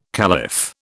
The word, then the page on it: caliph: British English